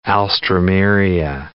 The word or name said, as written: alstroemeria